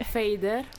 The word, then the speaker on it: Fader: tu che hai vissuto, cioè sai parlare l'inglese, come si doveva pronunciare, come si doveva leggere? – Fader. – Giusto, Fader.